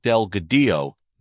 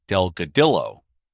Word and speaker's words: Delgadillo: For example, a popular advocate along Route 66 is Angel Delgadillo, not Delgadillo.